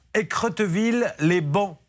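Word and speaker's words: Écretteville-lès-Baons: Vous êtes à Écreteville, les bancs. Ça se prononce ainsi oui, c'est ça.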